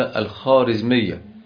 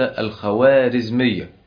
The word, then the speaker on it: الخوارزمية: المهم كان بجوارهم دولة مسلمة تسمى بالدولة الخارزمية، التي يسميها كثير من الناس الدولة الخوارزمية. هذه الواو هي الواو المعدولة التي لا ينبغي أن تنطق، واو تركية لا تنطق.